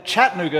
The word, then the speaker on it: Chattanooga: Some of you might say, don't you mean Chattanooga? No, Yankees. Chattanooga is how you say it if you're from Tennessee.